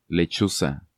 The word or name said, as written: Lechuza